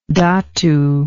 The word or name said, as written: datu